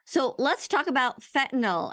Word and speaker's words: fentanyl: You pronounced it correctly. Fentanyl. – Okay. – So it has actually two correct spellings, F-E-N-T-A-N-Y-L or N-I-L.